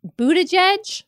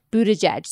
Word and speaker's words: Buttigieg: he specifically told the audience how to say his last name, and it is Pete Buttigieg.